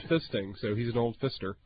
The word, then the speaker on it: Pfister: It's spelled P-F-I-S-T-E-R, and I'm staying there next week. The Hotel Fister.